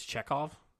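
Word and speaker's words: Czekaj: Check. That's how you pronounce his name. It's C C Z E K A J. It's Chekhov. I think so.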